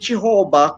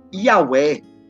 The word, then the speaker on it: Yahweh: sa mga Jewish scholars, yung Hebrew scholars, the correct pronunciation niyan ay hindi Jehovah, kung hindi Yahweh.